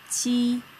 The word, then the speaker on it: q: The first set is ji, qi, xi. The letter J is pronounced as ji, like the beginning of jeans. Ji, jiao. Then we have the letter Q, which is pronounced as qi, qi, like cheese.